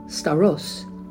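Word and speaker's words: stauros: Beloved, do you know in the Greek, in my Strong's Concordance, cross, which is the Greek word staros, S-T-A-U-R-O-S, I guess I'm saying it right, Staros, it is defined as stake or post.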